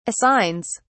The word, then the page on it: assigns: British English